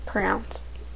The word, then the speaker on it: crayon: Crowns. – What? – Crowns. – Crowns? Like I wear a crown on my head? – Yes. That's how I pronounce it. Crowns. I can't say it the other way.